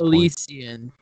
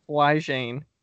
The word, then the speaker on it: Elysian: Can you pronounce the brewery name? I can't at this Elysian. – Point. Elysian. Elysian? I would not have said that. Elysian. – I would have said Flygene.